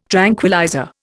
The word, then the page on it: tranquillizer: Indian